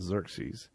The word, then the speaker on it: Xerxes: And this king, his name was, Xerxes, and it's actually spelled X-E-R-E-S. You pronounce it Xerxes.